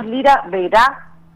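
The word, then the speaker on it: Lira Verá: Somos Lira Vera, con acento en la A, Lira por el instrumento, uno de los instrumentos más antiguos, digamos, que se conoce.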